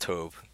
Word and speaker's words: Tobe: It's directed by, is it Tobe Hooper? Toby Hooper? It's T-O-B-E. – I've heard it pronounced Tobe. – It's so, okay. Yeah. Let's go with Tobe Hooper.